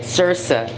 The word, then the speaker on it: Saoirse: É que nem é, Shivan, que é S-I-O-B-H-A-N, ou Saoirse, que é o nome daquela outra atriz também irlandesa, que é Sursa. Sursa. É, tipo Circe, tá ligado?